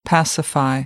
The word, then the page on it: pacify: American English